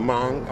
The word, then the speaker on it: Hmong: He worked with the, how do you pronounce it, the Hmong? – Oh, the Hmong, okay. H-M-O-N-G.